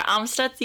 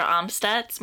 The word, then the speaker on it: Amstutz: I'm Sandra Amstutz. You can find me on all social platforms at Sandra Amstutz.